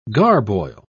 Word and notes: garboil: British English

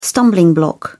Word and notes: stumbling block: British English

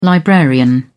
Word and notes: librarian: British English

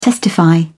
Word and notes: testify: British English

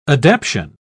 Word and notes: adaption: British English

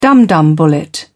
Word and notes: dumdum bullet: British English

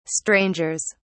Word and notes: strangers: British English

